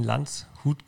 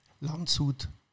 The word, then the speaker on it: Landshut: Du bist ein echter Bayer, in Landshut geboren. – Landshut, nicht Landshut. – Landshut? – Du hast Landshut gesagt. Das H spricht man nicht mit. Landshut.